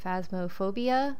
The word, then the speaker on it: Phasmophobia: A friend of mine gifted me, was it Phasmophobia? I think that's how you say it. – Yeah, that's correct.